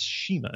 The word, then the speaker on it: CIMA: Um, I always pronounce. I want to pronounce his name Shima, but it's Shima.